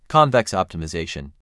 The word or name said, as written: Convex optimization